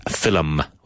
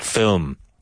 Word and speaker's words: film: Movie also, film, which most Irish people pronounce as film, with two syllables, but it's correctly pronounced film.